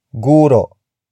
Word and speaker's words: guro: Word for word it's guro si John sa pamantasan. Guro si John sa pamantasan. Guro si John sa pamantasan. The first word is guro. Guro. Guro. Guro is spelled as G-U-R-O.